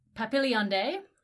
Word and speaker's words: Papilionidae: which is from the family Papilionidae.